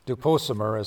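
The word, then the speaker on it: Dupont-Sommer: It's called The Essene Writings from Qumran, Duposomer is how you say it in French, and it's translated by Peter Smith.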